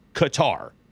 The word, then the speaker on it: Qatar: Love the show, but just want to let you know it is pronounced Qatar." – Interesting. – And he spelled it out phonetically.